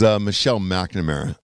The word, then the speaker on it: Michelle McNamara: I think her name was Michelle McNamara. That was the woman that he was married to. – I can't believe you said that right.